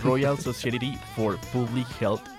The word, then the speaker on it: Royal Society for Public Health: Claro que sí, Nico, pero no estuvo tan mal, en verdad. Es el Royal Society for Public Health.